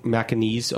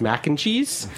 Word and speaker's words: Macanese: I think I was reciting Macanese over and over to myself, so I wouldn't pronounce it as mac and cheese.